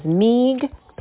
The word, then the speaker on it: Meeg: Hello, my name is Meeg Pincus. Meeg rhymes with league, and Pincus is like the color pink plus us.